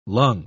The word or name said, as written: lung